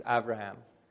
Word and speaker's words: Avraham: Or the Hebrew pronunciation is Avraham.